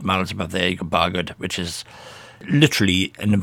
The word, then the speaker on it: Bargoed: And you were born in a little place called Bargoed, is that how you pronounce it? That's right, Bargoed, yeah.